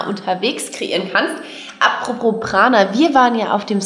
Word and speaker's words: Prana: Natürlich ist, wenn wir das jetzt in unserer westlichen Welt aussprechen, mit auch Prana up your life einfach mhm. schöner. Aber die originale Sprechweise ist tatsächlich Prana. Prana.